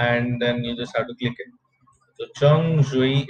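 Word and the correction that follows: 橙汁: is orange juice. Chung Ji, not Chung Shui. Shui is water.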